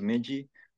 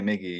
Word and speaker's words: Migi: And so, you know, I was like, why not put M-I-G-I, like Miji, you know, like, you know, but a lot, I know it's pronounced Miji, but like, I still say Miggy, you know, because, hey, why not?